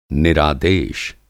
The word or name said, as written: निरादेश